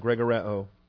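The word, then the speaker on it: Gregoreo (γρηγορέω): Subpoint one, Gregoreo. Gregoreo. 22 New Testament uses. G-R-E, that's the long E of the Eta. So Gamma, Rho, Eta. Gamma, done. G-R-E-G-O-R-E-O. G-R-E-G-O-R-E-O.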